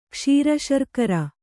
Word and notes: ಕ್ಷೀರಶರ್ಕರ: kṣīra śarkara

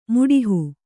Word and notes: ಮಾಡುಹ: Kannada

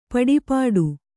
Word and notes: ಪಡುಪಾಟು: kannada

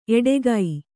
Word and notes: ಎಟುಕು: eṭuku

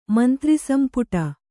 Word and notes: ಮಂತ್ರಿಸಂಪುಟ: Kannada

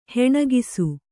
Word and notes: ಹೊಣಕಿಸು: hoṇakisu

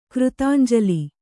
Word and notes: ಕೃತಾಂಜಲಿ: křtānjali

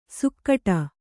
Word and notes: ಸುಕ್ಕಟ: Kannada

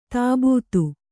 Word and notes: ತಬ್ಬೇತ: tabbēta

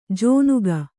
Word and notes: ಜೋನುಗ: Kannada

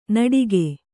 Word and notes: ನಟಿಕೆ: kannada

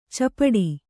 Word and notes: ಚಪಡಿ: capaḍi

